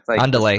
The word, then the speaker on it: Andel: Did I pronounce that correctly? Andel? – Andel. – Spanish.